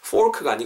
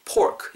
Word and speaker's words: pork: 포크가 아니고요. pork 이건 또 피사로 살리셔야 됩니다. 윗니, 윗입술 아랫입술이 닿았다가 터지는 사람들 양수로 pork 이렇게 발음해 주면 좋을 것 같습니다.